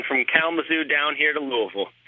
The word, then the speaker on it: Louisville: I'm calling from Louisville, Kentucky.